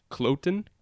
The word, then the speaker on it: Kloten: Dude, how do you say the Swiss team name? – Kloten.